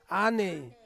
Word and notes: a nei: Ansus

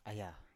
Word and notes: aya: Biak

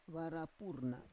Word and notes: vara pur na: Roon

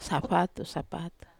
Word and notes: sapatu: Wamesa

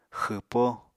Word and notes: xwpó: Apatani

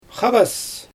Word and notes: xabas: Xyzyl

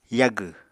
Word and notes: yagw: Apatani

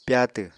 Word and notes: pyatw: apatani

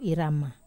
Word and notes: rama: wamesa

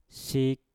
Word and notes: sik: mpur

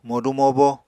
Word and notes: modu-mobo: Apatani

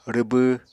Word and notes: rwbw: Apatani

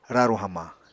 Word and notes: raru hamañ: Apatani

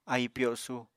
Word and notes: ahi pyosu: Apatani

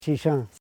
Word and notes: shisan: shi.san

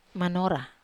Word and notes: manora: Wamesa

